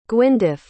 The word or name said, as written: gwyndaf